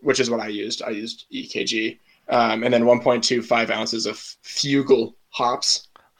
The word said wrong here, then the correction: Fuggle: I'm British. I'm going to correct you on your pronunciation. But yeah, Fuggle.